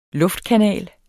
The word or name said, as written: luftkanal